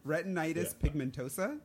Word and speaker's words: retinitis pigmentosa: Retinitis pigmentosa.